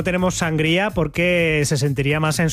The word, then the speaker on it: sangría: Bueno, vosotros decís sangría, ¿no? Lo pronunciáis mal. – Sangría. – Decís sangría. – Sangría. – No, pero es que le ponéis ahí el acento en la A, en la primera A.